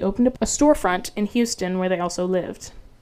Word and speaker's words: Houston: If you pronounce it Houston Street, they're like, it's pronounced Houston.